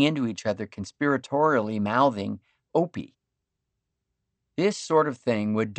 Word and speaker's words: Opie: And they all figured out that Opie rhymes with dopey, mopey, and soapy.